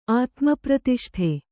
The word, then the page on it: ಆತ್ಮಪ್ರತಿಷ್ಠೆ: ātmapratiṣṭhe